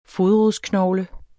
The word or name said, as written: fodrodsknogle